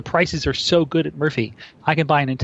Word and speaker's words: Murfie: And Murphy, which is spelled M-U-R-F-I-E, is out of Madison, Wisconsin.